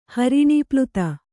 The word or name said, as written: ಹರಿಣೀಪ್ಲುತ